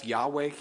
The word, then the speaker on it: Yahweh: Whenever you see God's name like that, when it's capitalized, L-O-R-D, that's Yahweh is how you say that. Or some people say Jehovah, that's adding other vowels to it.